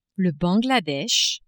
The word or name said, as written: Bangladesh